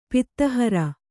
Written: ಪಿತ್ತಹರ

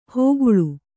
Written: ಹೋಗುಳು

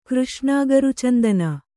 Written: ಕೃಷ್ಣಾಗರುಚಂದನ

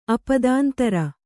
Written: ಅಪದಾಂತರ

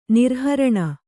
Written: ನಿರುಹರಣ

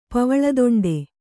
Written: ಪವಳದೊಂಡೆ

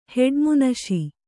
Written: ಹೆಡ್ಮುನಶಿ

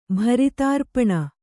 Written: ಭರಿತಾರ್ಪಣ

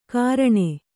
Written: ಗಾರಣೆ

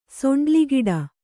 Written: ಸೊಂಡ್ಲಿಗಿಡ

